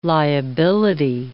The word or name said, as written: liability